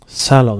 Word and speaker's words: Salon: Salon, hur man uttalar det på engelska. S-A-L-O-N, nätpublikation.